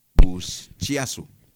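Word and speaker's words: Buschiazzo: es que se pronuncia, ¿no? Buschiaso.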